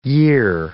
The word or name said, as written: year